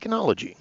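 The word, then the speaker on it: oenology: And O-E, when you read the Latin, is actually E. So Eno beer, like enology.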